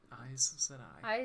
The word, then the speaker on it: Aes Sedai: Aes Sedai. – Aes Sedai.